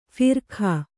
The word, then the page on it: ಫರಕ: Kannada